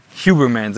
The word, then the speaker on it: Huberman: Huberman.